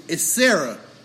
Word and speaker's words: Saira: Her name is Sarah. – Of course. – But it's spelled different. It's like S-A-I-R-A.